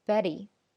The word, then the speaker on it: Betty: Betty，这是我们以前认为要这样读，但是在美语发音中呢，Betty 的这个 t 它是不读 t 的，它是读一个非常短的 d 的音，它又不是 d 啊，它只是接近英语 d 的这个发音，所以我们会说 Betty，包括后边的那个 better，butter 都是这样的读法，啊，就好像我们经常说英美音的一个区别，美音呢会说 water，后面是一个非常短的 d 的这样的一个声音。